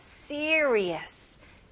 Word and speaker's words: serious: 네, 여기 중간에 R 발음이 조금 어려울 수도 있어요. 네, R 같은 경우는 혀가 천장 닿지는 않아요. 대신 혀가 뒤로 가는 느낌이 나야 돼요. 그래서 see, re, us. 연결해서 serious, serious.